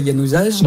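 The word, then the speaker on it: Januzaj: Alors, je suis désolé, je suis dyslexique donc je dois mal prononcer Yanouzage. – Non, c'est bon.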